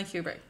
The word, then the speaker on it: Kubrick: Did I pronounce that last name right? – That's how I say it. – Stanley Kubrick.